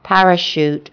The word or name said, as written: parachute